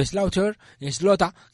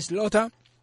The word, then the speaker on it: Slaughter: Bueno, Slota, que se pronuncia así en americano chungo, Slota.